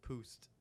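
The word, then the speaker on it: Pabst: Pabepst. Is that it? Pabepst. – No, it's poost.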